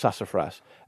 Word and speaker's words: sassafras: I believe it's pronounced sassafras tea.